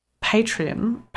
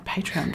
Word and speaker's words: Patreon: Well, we also wanted to talk about Patreon. Oh my God, why did I say it like that? Patreon.